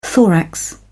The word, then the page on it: thorax: British English